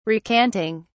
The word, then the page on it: recanting: British English